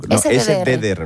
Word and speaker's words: SDDR: SDR, ¿no? – SDDR, lo he dicho bien, al principio, sí.